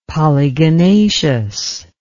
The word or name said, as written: polygonaceous